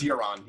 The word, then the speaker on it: Euron: Euron. Is that how you say it? – That's sociopath. It's Euron, yeah.